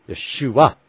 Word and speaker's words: Yeshua: Or those who say Shua, which we've studied that out as not being correct, but they'll go Yeshua, Yeshua, Yeshua, Yeshua. You know, the accent is on the Shua, on that swine, that second syllable.